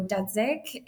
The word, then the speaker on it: Dudzik: I often anglicize it. So Dudzik is how most people say it.